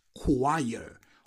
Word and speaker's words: choir: Coro, que se escribe choir, el coro de una iglesia, pues se pronuncia choir. Choir, una pedazo de K que tiene que sustituye a la C y a la H.